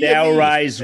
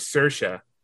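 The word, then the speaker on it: Saoirse: So she says her name is Sersha. So she says, just think of inertia and you'll be able to pronounce my name.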